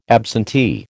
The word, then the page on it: absentee: American English